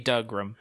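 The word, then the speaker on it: Dougram: It's got to be Dugrum. – Yeah, sure, because it's piloted by Doug.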